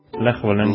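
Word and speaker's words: Lech Wałęsa: Lech Walesa.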